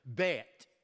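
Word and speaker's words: Bet (בית): The second strophe begins with the second letter, Bet. It's spelled Beth, but pronounced Bet.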